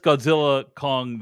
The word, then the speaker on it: Godzilla x Kong: you're supposed to pronounce it with the X silently, just Godzilla Kong, The New Empire.